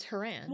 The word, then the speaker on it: Horan: Oh, did you see that we've been pronouncing her name incorrectly this whole time? – Horan? It's Horan. – Well, it's Horan, but I could have sworn you just said Horan. – Oh, I might have. I mean, people can listen to this and rewind it and call me on it, so there's no point denying it. – I'm just, like, that was a learning moment for me, that her name is pronounced Horan instead of Horan.